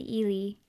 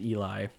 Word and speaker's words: Ely: So these are from up in Ely, Minnesota. – Not pronounced Eli.